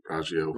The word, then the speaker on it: Raggio: Raggio? R-A-G-G-I-O. How would you pronounce that? – Raggio. Raggio. Sounds good to me.